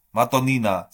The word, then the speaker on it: Madonnina: Wir gucken mal kurz nach Italien, denn Herr Baden erklärt uns, was gab's vor ein paar Tagen, und zwar das Derby della Madonna. Madonnina. So spricht man es aus.